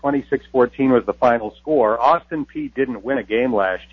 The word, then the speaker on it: Peay: Is it Austin Pay or Austin P? – It is Austin P. Even though it is spelled P-E-A-Y, it is Austin P.